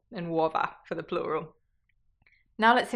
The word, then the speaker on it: uova: Uova.